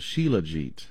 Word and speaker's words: shilajit: they use a substance called shilajit. It's spelled J-I-T. Shilajit. Shilajit.